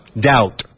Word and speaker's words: doubt: Doubt. B muda, doubt.